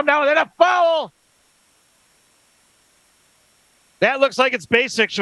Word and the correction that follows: Basich: with the call. Bennett Basics. Basich, maybe. That's his first. Bennett Basich is the pronunciation.